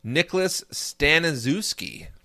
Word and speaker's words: Staniszewski: Nicholas Staniszewski, and I don't think I'm saying that wrong. – No. As a Polish person, I can tell you that you're not.